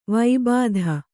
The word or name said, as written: ವಿಭೂತಿ